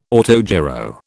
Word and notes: autogyro: British English

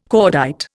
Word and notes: cordite: Indian

